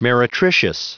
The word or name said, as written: meretricious